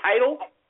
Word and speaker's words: Tidal: Is this thing called Tidal or Tidal? It's called Tidal.